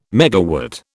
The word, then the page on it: megawatt: British English